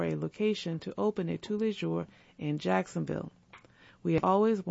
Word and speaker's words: Tous les Jours: Pronounced Tous les Jours, the bakery cafe chains dates back to 1996, and its first store was established in 1997 in Guri, near Seoul, South Korea.